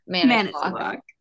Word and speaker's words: Manitowoc: Manitowoc. Oh no. – Manitowoc. Is the correct pronunciation.